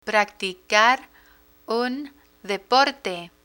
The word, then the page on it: practicar un deporte: Spanish